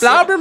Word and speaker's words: Blaubeer: So I know how to say it's Blaubeer muffins. That's how you say blueberry muffins. Blaubeer muffins.